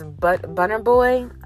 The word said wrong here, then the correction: Burna Boy: I don't want to be pronouncing it wrong. Is it Butter Boy? But yeah, let me just check, because I don't want to be pronouncing the fucking shit wrong. But yeah, Kendrick Lamar, Burna Boy. Yeah, it is Burna Boy.